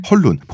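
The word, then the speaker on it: Hoelun: 헐룬. 보통은 이제 커룬이라고도 하고 후엘론이라고도 하는데 표기법과 발음에 따라서 달라요. – 우리는 헐룬이라고 하는 걸로. – 우리는 그냥 헐룬이라고 사실은 커룬에 가까운데 그냥 헐룬이라고 편하게 하는 걸로 정하시죠.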